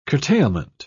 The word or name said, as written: curtailment